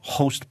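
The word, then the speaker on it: Khost: The Host Province, and we're pronouncing it in the Pashto-y, which is Host Province, but if you try to look it up, it begins with a K-H. So if you're trying to look it up, K-H-O-W-S-T.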